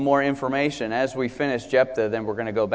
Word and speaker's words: Jephthah: This is the account of Jephthah. By the way, it's spelled J-E-P-H-T-H-A-H.